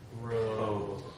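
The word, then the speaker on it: rho: Row. – Row. Every time you see this, it's a R.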